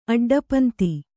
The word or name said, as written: ಅಡ್ಡಪಂತಿ